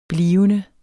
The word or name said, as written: blivende